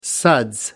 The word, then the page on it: suds: American English